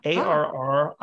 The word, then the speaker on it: Arriaga: It's actually Basque. If it was Italian, we'd pronounce the L's. It's Basque, but it's pronounced in the Spanish style. So it's Arriaga.